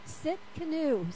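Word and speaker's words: Tsidkenu: It's T-S-I-D-K-E-N-U. How do you say it? Sit canoe.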